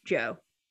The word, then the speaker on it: DJO: And now the band is DJO, but I think it's actually legitimately pronounced Joe.